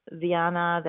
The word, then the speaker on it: Diana: My name is Diana Lopez, and Diana is the Spanish pronunciation of Diana. I actually bond to both pronunciations of my name, but I prefer Diana.